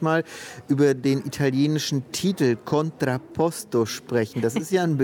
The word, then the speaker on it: Contrapposto: Ja, du hast ihn auch so wunderschön italienisch gerade ausgesprochen, Contrapposto.